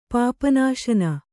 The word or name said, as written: ಪಾಪನಾಶನ